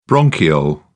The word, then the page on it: bronchiole: British English